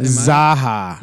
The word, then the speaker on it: Zaucha: Jeremy Zacha. – Zaha. Zaha. It's spelled Z A U C H A. So no one ever gets it right.